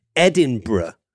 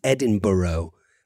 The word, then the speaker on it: Edinburgh: If you do manage to get yourself to Scotland, it's important to pronounce this city Edinburgh and not, as a lot of people in the United States say, which is Edinburgh.